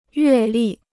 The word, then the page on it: 阅历: yuè lì